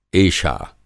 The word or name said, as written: एषा